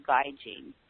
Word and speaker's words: gaijin: But you think about a cute guy in jeans, and that's how you pronounce it, Gaijin.